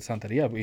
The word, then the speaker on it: Santeria: I mean, when I was a kid, man, my mom's friend had this, you know, she would go to that Santeria, you know, lady. And I like how you actually pronounce it correctly. I said Santeria.